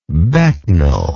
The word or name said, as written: becknell